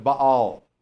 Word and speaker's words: Baal: "Who have not bowed the knee to the image of Baal." Well, we call him Baal, but the proper pronunciation is Baal.